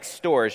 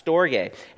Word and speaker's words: storge: It looks like storge, but it's actually pronounced storge.